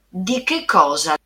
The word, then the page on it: di che cosa: Italian